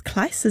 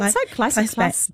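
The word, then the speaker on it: Claes: It stars, I think it's Kleiss, is that how you say it? – I think Kleiss, Danish.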